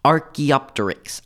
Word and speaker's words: Archaeopteryx: So, like, there's a lot of people point to the dinosaur known as Archaeopteryx. I hope I said that right. – You did. Good job.